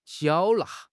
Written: 缴纳

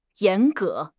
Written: yan2 ge2

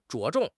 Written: 着重

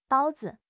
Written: bao1 zi5

